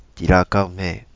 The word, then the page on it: Tirakaume: Kapingamarangi